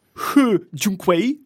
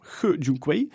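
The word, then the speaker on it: He Jiankui: Hedy, we hebben het al heel vaak gehad over de Chinees He Junkwei, zoals ik hem volgens mijn neef Frederik, die Chinees spreekt, moet uitspreken. Nee, He, he zeggen we zelf. He Junkwei.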